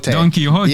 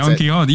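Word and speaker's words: Don Quijote: Don Quixote. – Yeah, that's Don Quixote. Yeah, like, you don't pronounce the J.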